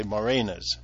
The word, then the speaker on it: moraine: But moraines, or some people say morainas, and I believe it's a French word, so I'm sure the French pronounce it completely differently.